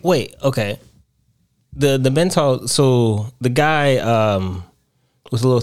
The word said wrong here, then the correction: Mentat: Are we saying that right? Mentals? – Mentat. – Mentat? – Mentat. – Mentat. Okay, a Mentat.